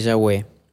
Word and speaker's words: Yahweh: Lo más importante del nombre de Yahweh es que la pronunciación original en hebreo suena como el sonido que uno eh, reproduce al respirar, al inhalar y exhalar.